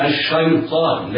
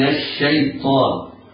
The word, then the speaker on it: الشيطان: الياء يتحرز القارئ من تفخيمها فلا يظن فلا تجذبها الطاء إليها، يعني لا يخطئ القارئ ويفخم الياء فلا يقول الشيطان، لا، وإنما تنطق مرققة هكذا: أعوذ بالله من الشيطان.